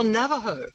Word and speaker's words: Navajo: Oh, Navajo.